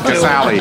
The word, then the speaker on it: Casale: Yeah, Casale. I think we should Malcolm. Yeah. Has taught us how to say that, so we should say it correctly.